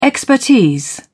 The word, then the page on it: expertise: British English